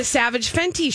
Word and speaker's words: Savage X Fenty: The X is just for show. It's a silent X. – Yeah. – The Savage Fenty show.